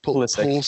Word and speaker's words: Pulisic: I think that Pulisic, is that how you say his name? – Pulisic.